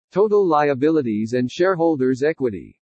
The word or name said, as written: TOTAL LIABILITIES AND SHAREHOLDERS' EQUITY